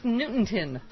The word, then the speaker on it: Newington: Mark in Newington. Am I saying that right? – Newington? – What? – Newington, Connecticut. There you go.